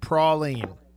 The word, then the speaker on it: praline: Apparently that is the correct answer, Brett, and it's pronounced praline.